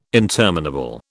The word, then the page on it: interminable: British English